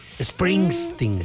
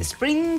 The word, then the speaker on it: Springsteen: Sí. Es con N. Springsteen. – Springsteen.